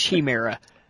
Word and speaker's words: chimera: And for fuck's sake, they could not pronounce the word chimera at all. Every fucking time they say it, it's like chimera or chimera or something. It's chimera. It's like, no! Fucking pronounce the word correctly.